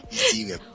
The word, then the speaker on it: EZweb: EasyWeb ですね。あ、 EZ じゃないんだ。ごめんね、 au 持ってないんで。はい、 EasyWeb。